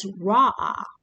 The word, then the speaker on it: ra'ah: So with the Old Testament, the word for vision, the Hebrew word, is ra'ah, and you spell it R-A, and there's an accent, A-H. Ra'ah, which means simply to look or to see.